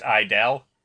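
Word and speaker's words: I-DLE: Apparently, I posted in the link there, when it's said out loud, apparently the G is silent. – Oh, so they're just I-Dol? – Yeah. – Okay, cool. – Or idol, I think is right.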